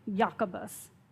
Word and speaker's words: Jacobus: Greek transliteration of James is Jacobus. That's how it would be pronounced in Greek, Jacobus.